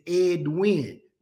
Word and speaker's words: Edwin: My name is Edwin. Two syllables.